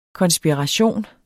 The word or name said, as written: KONSPIRATIONERNE